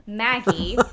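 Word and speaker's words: Magie: And it goes, in 1903, Elizabeth Maggie, and I might be pronouncing this wrong, it's M-A-G-I-E, so it's one G instead of two, but I'm going to say Maggie because that makes the most sense.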